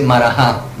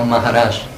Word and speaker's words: Maharaj: En español, en realidad, ya saben, se dice Marajá, pero la pronunciación de eso, como se escribe Marajá, entonces lo leemos Marajá, los Marajás de la India, pero en realidad la pronunciación sánscrita de eso no es Marajá, sino Maharaj.